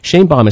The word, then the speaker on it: Shainbaum: Shainbaum is spelled S-H-A-I-N-B-A-U-M.